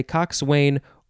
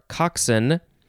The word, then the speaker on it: coxswain: It is cox, C-O-X. First form, noun from 1869. And the synonym is coxswain. And is that, you can say coxswain or coxswain.